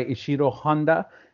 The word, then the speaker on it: Ishiro Honda: It was directed by Ishiro Honda and written by Shigeru Kayama.